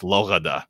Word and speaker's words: Lourdes: My French is not good enough to pronounce Lourdes, but Lourdes. – Wonderful. Yeah, Lourdes. Yeah, it's very interesting because they say that Lourdes was the name.